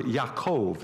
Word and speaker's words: Yaakov: He was smooth, and he was smooth in more ways than one, because his name, Jacob, is the Hebrew word Yaakov. That's what the Hebrew pronunciation is of Jacob.